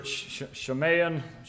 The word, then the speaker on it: Shamayim: The Hebrew for heaven is, I'll butcher it, Shamayim, another I am here, it's S-H-A-M-A-Y-I-M.